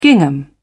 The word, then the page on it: gingham: British English